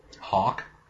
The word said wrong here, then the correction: Hulk: Hulk. – I did not think... Oh, Hulk. – Hulk. – I think I said Hawk. – Hawk. – Goddamn Americans. – Yeah. Goddamn Americans pronouncing your L's like W's.